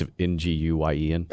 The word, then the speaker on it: Nguyen: Yeah, it's Nguyen. – It's just Nguyen? – Yeah. – Johnny Nguyen? – Yeah, Nguyen or Nguyen. – Well, which is it? – It's either one.